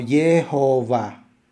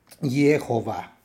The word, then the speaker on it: Yehová: deben ser pronunciadas como Yehová, con el acento en la última sílaba. Yehová.